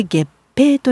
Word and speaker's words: 月餅: お団子以外で気になるお月見の食べ物といえば月餅。月の餅と書いて月餅と読むあのお菓子です。